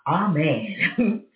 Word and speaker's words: amen: That's really the proper way to say amen. It's not amen. It's amen. Even if you listen to it and you put it in the thing of how to pronounce it, if you search it in the scriptures and you put up the pronunciation of how to say it, it's amen. And it could be spelled A-M-E-I-N.